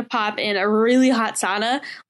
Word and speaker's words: sauna: And then this date was the sauna. The sauna is how I pronounce it. – Sauna? – Yeah. I think that's really that's the Finnish pronunciation.